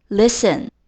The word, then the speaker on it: listen: Listen，在拼写的时候千万不要把 t 丢掉，l i s t e n。虽然有 t，但是它不会读成 listen。这个 t 在这里是不发音的。